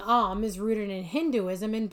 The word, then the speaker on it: Om: The yoga class starts and ends by chanting Om, which is pronounced Aum, A-U-M.